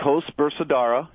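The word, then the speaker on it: Koes Bersaudara: I'm probably going to mispronounce it. It's the Brothers Coes Bersaudara. – It's Coes Bersaudara.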